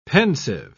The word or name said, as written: pensive